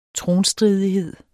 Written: tronstridighed

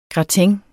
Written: gratin

